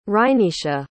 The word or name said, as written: rhynisha